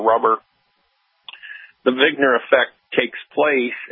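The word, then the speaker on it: Wigner: Vigner. It's actually like he just spelled with a W.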